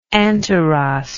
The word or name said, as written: Anteros